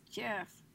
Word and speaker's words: GIF: It's pronounced GIF and not GIF. – Yif? – It's Jif.